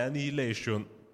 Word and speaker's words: Annihilation: Secondo me Annihilation, l'ho detto bene? – Sì, eh, questo l'hai detto bene.